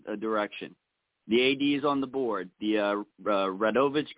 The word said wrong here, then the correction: Radakovich: guy, I'm sorry, I can't say his name. – Radakovich. – Radakovich guy, the guy from Baylor, Mark Rourke.